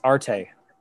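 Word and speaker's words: arte (ἀρετή): Arete. I don't know how to pronounce it. – It's arte. – Arte.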